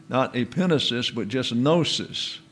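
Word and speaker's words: epinesis (ἐπίγνωσις): In other words, EPI is the concept followed by a gamma. That there, because it is an associated with the new that follows, is also pronounced as if it were new or an N. And so the word is epinesis.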